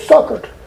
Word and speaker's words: succored: I used to say secured. That ain't what it says. It says succored. That's exactly how it's pronounced.